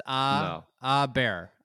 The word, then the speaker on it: Åberg: It's not O anymore. Thing. It's Åberg.